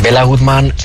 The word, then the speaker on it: Béla Guttmann: Hablamos en la introducción la de Bela Goodman. ¿Quién era, no sé si se dice así, quién era este personaje? – Pues Béla Guttmann fue un entrenador que nació en el año 1899 en Budapest.